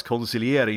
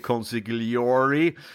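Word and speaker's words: consigliere: det ska uttalas conciliere, inte conciliari.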